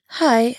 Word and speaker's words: hej: Hi is spelled H-E-J, but it's pronounced like hi in English.